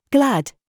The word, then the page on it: gig: British English